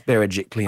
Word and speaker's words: Berejiklian: Because not many people can pronounce Berejiklian properly.